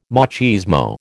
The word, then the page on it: machismo: American English